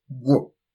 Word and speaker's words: gw: The GW. And that is pronounced Gwo.